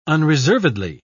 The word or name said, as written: unreservedly